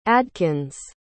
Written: adkins

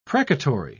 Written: precatory

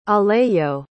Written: alejo

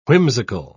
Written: whimsical

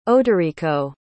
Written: oderico

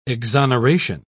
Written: exoneration

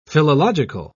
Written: philological

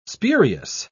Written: spurious

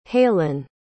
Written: heilyn

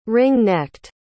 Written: ring-necked

